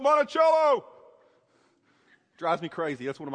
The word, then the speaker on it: Monticello: It's pronounced Monticello. I always get a laugh when I come here because everybody in the Midwest calls it Monticello.